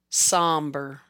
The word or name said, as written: Somber